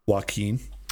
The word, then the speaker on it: Joaquin: Joaquin.